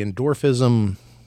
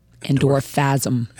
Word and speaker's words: Endorphasm: We're even going to talk about, you've created this thing called the Endorphism Community. – So you want to say it like you don't want to say it. Endorphasm.